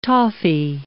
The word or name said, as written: toffee